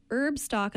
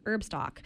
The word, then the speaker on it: Herbstalk: It's Herbstock, and I'll spell it for you, H-E-R-B-S-T-A-L-K, like the stock of a plant instead cool. – of Woodstock, you know. It's Herbstock.